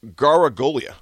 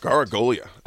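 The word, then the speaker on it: Garagiola: Gargiola. – Well done. – Perfect. Gargiola. – I don't know. – At least I think that's how you pronounce it. Joe Gargiola.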